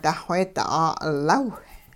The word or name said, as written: duxweedi 'aa'ulaw